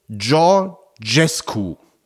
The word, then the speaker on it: Georgescu: Der ausgeschlossene Präsidentschaftskandidat wird ausgesprochen Georgescu. Das J jeweils weich, wie Georg im Englischen.